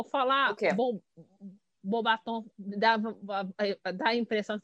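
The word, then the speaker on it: Beauxbatons: Pra falar a verdade, até acho que o Jake pronuncia em inglês, Bobatons. Eu prefiro Bobaton, que é o francês.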